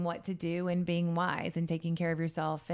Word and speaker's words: YSE: Wise, it's spelled Y-S-E. It's pronounced to be, you know, wise.